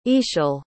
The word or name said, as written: Ixchel